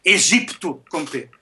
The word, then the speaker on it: Egipto: Desde que me conheço, tal como Egipto, sempre ouvi pronunciar, desculpem que me digam, Egipto com P.